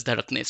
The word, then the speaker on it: dhadakne: Like if you think of the words dhadkane and dhadakne, you pretty much write them in exactly the same way, but one you pronounce as dhadkane, the other one you pronounce as dhadakne.